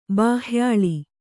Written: ಬಾಹ್ಯಾಳಿ

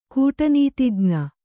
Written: ಕೂಟನೀತಿಜ್ಞೆ

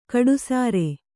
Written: ಕಡುಸಾರೆ

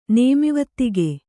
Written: ನೇಮಿವತ್ತಿಗೆ

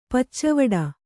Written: ಪಚ್ಚವಡ

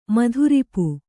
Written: ಮಧುರಿಪು

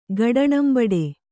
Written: ಗಡಣಂಬಡೆ

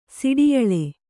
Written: ಸಿಡಿಯಳೆ

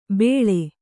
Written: ಬೇಳೆ

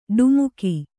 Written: ಡಿಮಕಿ

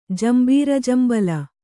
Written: ಜಂಬೀರಜಂಬಲ

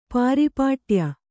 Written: ಪಾರಿಪಾಟ್ಯ

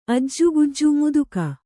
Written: ಅಜ್ಜುಗುಜ್ಜು ಮುದುಕ

